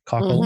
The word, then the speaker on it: Cockle: written by Jeannie Cockle and, did I say that right? Cockle mm-hmm. and Joan MacArthur Blair.